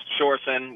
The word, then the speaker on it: Sjursen: And your articles, your Major Sherson's name is spelled S-J-U-R-S-E-N. Did I get that right? – That's right, yeah, S-J-U-R-S-E-N. Pronounced Shorson, which is ridiculous.